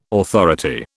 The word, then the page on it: authority: British English